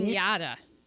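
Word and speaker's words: La Cañada: There is the Jet Propulsion Lab and the NASA Field Center at it's spelled La Canada, but I know that's not how you say it. – La Kenyatta.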